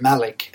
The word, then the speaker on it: Malik: Well, just briefly, I guess I told you guys over lunch, my mum, my last name wasn't Malik. My last name is spelled M-A-L-I-K. It used to be Abdelmalek. A-B-D-E-L-M-A-L-E-K.